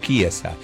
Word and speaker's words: Chiesa: Italiana, Chiesa, se escribe Chiesa, pero como es italiano es Chiesa.